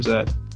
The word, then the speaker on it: Z: Yeah, like I wouldn't correct someone if they said Z or Zed.